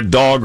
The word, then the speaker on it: Dogue: Get the bums, the duty, and the needles off the streets before you make a dog restaurant. – The restaurant is called Dog, but it's but it's spelled D-O-G-U-E, like a snooty French person.